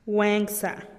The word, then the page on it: guenkza: TdVZ